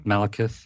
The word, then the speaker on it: Malekith: Malekith, yeah.